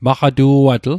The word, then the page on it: maqatu'atl: Mi'gmaq